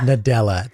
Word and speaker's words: Nadella: Satya Nadella. Think of Nutella, but less delicious.